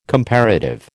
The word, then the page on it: comparative: American English